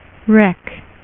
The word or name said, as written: Wrecker